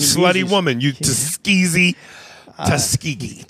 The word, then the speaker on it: Tuskegee: But what do you think about people who keep bringing up the Tuskegee? I can't say it right. – Tuskegee.